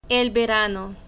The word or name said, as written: el verano